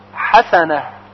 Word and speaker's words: حسنه: و همچنین کلمه حسنه با هاء جیمی هست، حسنه. پس اگر بگوییم حسنه با هاء دو چشم این هم غلط هست.